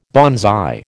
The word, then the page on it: banzai: American English